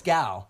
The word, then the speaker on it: Gal: Is it actually Gail or something like that? No, it's Gal.